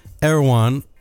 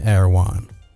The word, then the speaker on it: Erewhon: Anyway, Erewhon. I say it wrong every time, so I have to do it in an American accent. I'm in Erewhon. Erewhon.